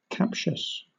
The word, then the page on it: captious: British English